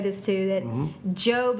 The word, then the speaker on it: Job: And the instructor said that it looks like job, but it actually is pronounced Job.